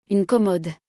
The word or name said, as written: Une commode